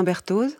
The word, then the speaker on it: Berthoz: Et bonjour Alain Berthoz. – Bonjour. – Je prononce bien le Z à la fin? – Oui, tout à fait.